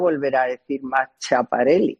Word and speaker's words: Schiaparelli: No se dice Schiaparelli. – Perdón. – Schiaparelli. – Ay, pobre Laurita, que no sabe italiano.